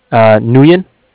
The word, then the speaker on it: Nguyen: Nguyen. – Spell that. – N-G-U-Y-E-N.